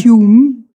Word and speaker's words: Hume: Also man schreibt ihn H-U-M-E, David Hume. Hume.